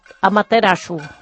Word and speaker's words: Amaterasu: Que es diu Amaterasu. – Amaterasu. – Amaterasu.